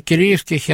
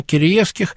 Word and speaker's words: Киреевских: никак не сойдемся с ним, где ставить ударение. Он на Киреевских, я на Киреевских, но это вечный спор тех, кто изучает старших славянофилов.